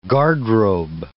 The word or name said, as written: garderobe